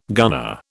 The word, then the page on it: gunner: British English